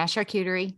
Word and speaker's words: charcuterie: So as I'm looking at this map in regards to the charcuterie tray, am I saying that right? – Yeah, charcuterie.